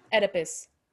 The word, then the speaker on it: Oedipus: Oedipus.